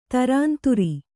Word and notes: ಧುರಂಧುರಿ: Kannada

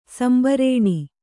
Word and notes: ಸಂಭಾರಣೆ: sambhāraṇe

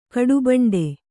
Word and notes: ಕಡುಬಂಡೆ: kaḍubaṇḍe

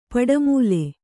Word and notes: ಪಡಮೂಲೆ: paḍa mūle

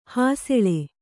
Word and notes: ಹಸಲು: kannada

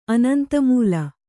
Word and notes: ಅನಂತಮೂಲ: anantamūla

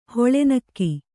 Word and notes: ಹುಲ್ಲೆನೆಗೆ: hulle nege

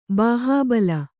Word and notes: ಬಾಹುಬಲ: kannada

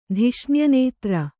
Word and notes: ಧಿಷ್ಣ್ಯನೇತ್ರ: Kannada

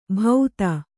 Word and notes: ಭೌತ: kannada; bhauta

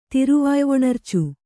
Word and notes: ತಿರುವಾಯ್ವೊಣರ್ಚು: Kannada

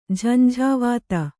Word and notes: ಜಂಝಾವಾತ: Kannada

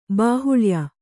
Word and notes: ಬಾಹುಲ್ಯ: bāhulya

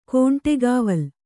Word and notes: ಗಂಡಿಕಾವಲಿ: gaṇḍi kāvali

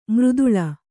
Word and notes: ಮೃದುಳ: mřduḷa; Kannada

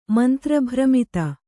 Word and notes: ಮಂತ್ರಭ್ರಮಿತ: kannada; mantra bhramita

